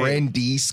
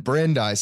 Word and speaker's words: Brandeis: I was saying Brandeis because it's spelled like Brandeis, but Brandeis. See, I got it right.